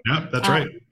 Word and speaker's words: sommelier: We have this great relationship and Marco killed me because I never pronounced it correctly, but sommelier, is that how you say it? – Yeah, that's right.